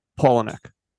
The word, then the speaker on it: Palahniuk: He told me a really funny story about Chuck Palahniuk. Is that how you pronounce it? – Palahniuk.